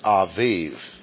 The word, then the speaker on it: Abib: Nisan is the same as A-B-I-B, pronounced Aviv.